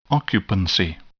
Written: occupancy

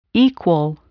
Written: equal

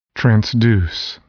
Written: transduce

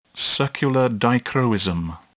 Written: circular dichroism

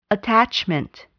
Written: attachment

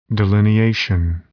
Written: delineation

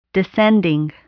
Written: descending

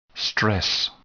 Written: stress

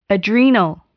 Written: adrenal

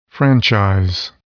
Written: franchise

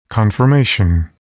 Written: confirmation

